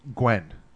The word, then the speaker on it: Nguyen: I couldn't pronounce it. N-G-U-Y-E-N, like the guy on 21 Jump Street. – Gwen. – That's really how it's pronounced? – Gwen.